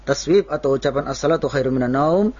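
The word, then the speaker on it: minan naum (من النوم): Ba'da hayyalataini, ketika mengucapkan, telah mengucapkan hayyalas salah, hayyalas salah, hayyal falah, hayyal falah, baru mengatakan as-salatu khairum minan naun, minan naum.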